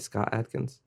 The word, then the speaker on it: Scott Adkins: Scott Adkins? – Yeah.